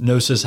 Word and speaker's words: Gnosis: And Gnosis has a silent G in front of it, G-N-O-S-I-S.